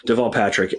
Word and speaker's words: Deval Patrick: And did you just call him Devil Patrick? – I did say Devil Patrick. I know. It's because I was just reading it off a screen, so that's just how it phonetically it came to me.